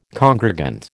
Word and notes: congregant: American English

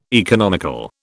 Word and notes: economical: British English

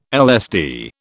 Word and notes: LSD: British English

